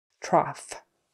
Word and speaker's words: trough: Trough. Cough, coughing, trough, those are all off with an F sound on the end. The next way is ooh. You can pronounce O-U-G-H, ooh.